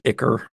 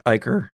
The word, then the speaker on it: ichor: and it is drooling out a greenish ichor. Sorry. It is drooling out a greenish ichor, because that's the correct pronunciation.